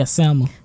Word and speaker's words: Selma: Is this just Selma? – Yes, yeah, Selma.